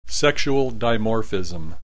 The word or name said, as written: sexual dimorphism